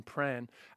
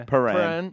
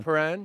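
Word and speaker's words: Prahran: There's a Paran in there. – Paran. – Like a piranha. – Yeah. Paran.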